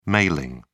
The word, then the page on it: mailing: British English